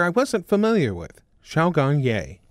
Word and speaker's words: Ye: Xiaogang Ye is the last name. The surname is Y-E, Ye.